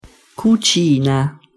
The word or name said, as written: cucina